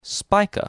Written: Spica

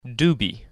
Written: Deubhe

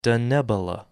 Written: Denebola

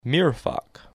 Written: Mirfak

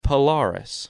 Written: Polaris

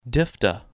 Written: Diphda

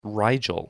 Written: Rigel

